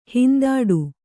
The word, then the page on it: ಹಿಂದಡಿ: kannada